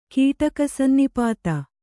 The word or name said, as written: ಕೀಟಕಸನ್ನಿಪಾತ